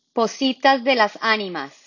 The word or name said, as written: Pozitas de las Animas